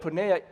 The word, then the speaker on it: porneia: And the Greek word is porneia. And as you look at the word porneia, it rhymes with the word pornography.